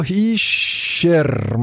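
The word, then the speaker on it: Hischier: Nico Hischer, muss man den mal ja korrekterweise aussprechen. Nico Hu hat da jemand aufgeschrieben, ein bisschen boshaft.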